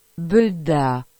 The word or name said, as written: Bedat